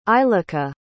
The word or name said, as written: eilika